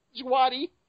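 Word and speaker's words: Djawadi: Once again, Jawadi, or how you pronounce his last name, sorry if I pronounced it wrong. – The D is silent. – The D is silent. Jawadi.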